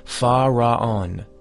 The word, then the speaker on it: Fa Ra On: It is sufficient to go to sleep, mentally pronouncing the powerful mantram, Fa Ra On. This mantram is divided into three syllables, Fa, Ra, on.